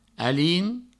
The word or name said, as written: alin